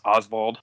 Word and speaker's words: Osvald: I believe his name is pronounced as Osvald. It's like Osvald.